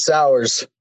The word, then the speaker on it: Sauers: Did I get it right or wrong? – You got it, Sauers.